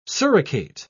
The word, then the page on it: suricate: British English